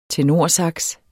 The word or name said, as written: tenorsax